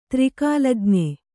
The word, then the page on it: ತ್ರಿಕಾಲಜ್ಞೆ: tri kālajn`e